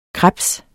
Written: krebse

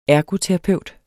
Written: ERGOTERAPEUTEN

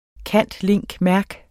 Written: cand.ling.merc.

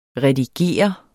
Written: redigere

